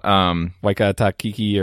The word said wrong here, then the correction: Taika Waititi: or how do you say the guy's name? Anyway. – Taika Waititi.